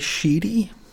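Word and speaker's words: Schiti: Valerio S-C-H-I-T-I. I don't want to say it phonetically. Valerio will say Sheedy.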